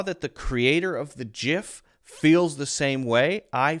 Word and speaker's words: GIF: I have always pronounced this as GIF, the soft G sound, also known as the J sound. GIF, GIF, GIF.